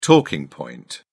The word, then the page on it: talking point: British English